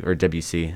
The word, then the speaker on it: Debussy: It's as well as Claude Debussy, Debussy as it's pronounced, if you're familiar with him.